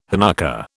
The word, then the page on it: Hanukkah: British English